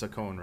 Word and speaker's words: Sakon: So I always I used to say Saccone when I was a kid, but I mean, if it's Japanese, it's Saccone, right? S-A-K-O-N, Saccone. – Saccone, I've always said, I think Saccone, Saccone.